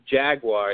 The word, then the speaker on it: Jaguar: The vehicle, the Jaguar, is originally British, and it should be pronounced when you're in London, Jaguar.